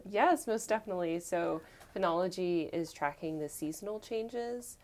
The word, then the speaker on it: phenology: It's phenology,